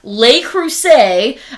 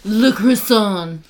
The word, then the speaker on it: Le Creuset: So I sold Le Creuset. I know I'm still saying that like an American, but they were pronouncing it Le Croissant.